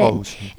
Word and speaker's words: Aarhus: I've never been to Copenhagen Copenhagen, but uh, my sisters lived in um, Aarhus, I think yeah. is how you pronounce it. Aarhus.